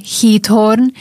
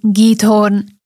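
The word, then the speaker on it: Giethoorn: Za jeden z najpokojnejších kútov Európy môžeme považovať mestečko, dedinku, ktorá podľa toho, ako som sa dočítala, sa vyslovuje Heathorn, alebo vraj po slovensky to niekedy je aj Githorn.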